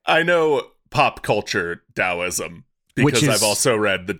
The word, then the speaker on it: Taoism: It's spelled with a T, Taoism, but it's pronounced Taoism, which has work recorded as early as around 1000 BC.